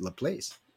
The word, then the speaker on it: LaPlace: Well, I won like it was a $2,000 for somebody over Laplace because it's spelled L-A-P-L-A-E-C-E. It's Laplace. That's what it spelled like. I said, people, but it's French. French is Laplace. – I said, remember, it's French. It doesn't sound like that.